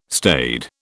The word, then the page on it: staid: British English